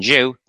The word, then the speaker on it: Jew: In the English language today, the J in Jew is pronounced like the J in English, justice, jolly, and jump.